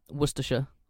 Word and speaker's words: Worcestershire: Especially Worcestershire or Worcestershire. – Yes, it's either sheer or sure.